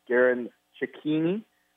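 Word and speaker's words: Garin Cecchini: That is the case with Garen Ciccini, which is the pronunciation, I'm told, from the Brewers' fine radio man, Jeff Levering, who covered him in Pawtucket.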